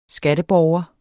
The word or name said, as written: skatteborger